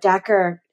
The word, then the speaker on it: Dacher: And I realized that when I was listening to your interview with Dacher, is that how you pronounce it? Keltner? – Yes. Yes.